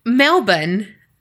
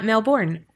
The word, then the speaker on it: Melbourne: But to top it off, Carrie just told me it's Melbourne, not Melbourne. Well, not Melbourne.